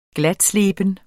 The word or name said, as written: glatsleben